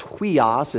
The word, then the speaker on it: huios: It's pronounced huios. It's H-U-I-O-S.